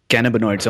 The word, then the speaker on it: cannabinoids: So, three key things which I noted from what you said was there are over 100 different types of cannabinoids. Am I pronouncing that yep. properly? Yeah.